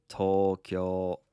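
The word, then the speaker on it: Tokyo (東京): Tokyo has 2 long vowels, one on the to and then another one on the kyo. And it's Tokyo.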